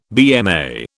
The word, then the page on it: BMA: British English